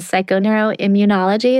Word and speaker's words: psychoneuroimmunology: I mean, I follow your work and you really want to dig into some of this quantum physics and psychoneuroimmunology. Is that how you say it? – That's right. Yeah.